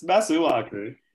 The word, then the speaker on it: Masuaku: It's Masuaku.